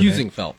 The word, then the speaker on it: Heusinkvelt: You tell me your Heusinkvelt.